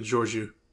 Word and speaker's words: Georgiou: Captain Georgiou.